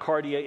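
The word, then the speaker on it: cardia (καρδία): Now, the first one is pretty easy to pronounce. It's called cardia. Now, I can't put the, it's not cardia, it's cardia. Say that with me, cardia.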